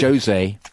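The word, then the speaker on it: José: Jose Mourinho on Saturday. – Jose. It's not Jose, because he's Jose. – Not Spanish, he's Portuguese, and you pronounce the J.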